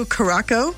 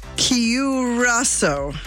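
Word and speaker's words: Curaçao: I was saying blue curacao. – Yeah. – And it's curacao. – Curacao. – Curacao. Blue curacao.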